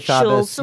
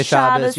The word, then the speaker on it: Shabbos: So Ashkenazi pronunciation is the default in like families. – You still say Shabbos. You say Shabbos. You say Shavuos.